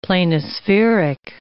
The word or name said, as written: planispheric